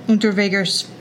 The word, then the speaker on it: Unterweger: In 1992, Unterweger fled to Miami, but was caught and arrested. You have anything to say? – One, you say his name so well.